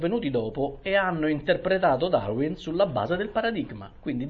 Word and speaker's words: Darwin: Allora prendiamo Darwin, o Darwin, come lo vogliamo pronunciare, facciamo una cosa all'inglese.